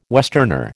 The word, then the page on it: westerner: American English